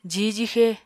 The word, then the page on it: jihw-jixe: apatani